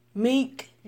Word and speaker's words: Meek: Meek.